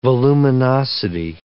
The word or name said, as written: voluminosity